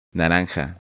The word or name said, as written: Naranja